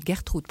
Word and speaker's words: Gertrude: Car Gertrude Dubi-Blom, Gertrude parce qu'on faut prononcer en allemand,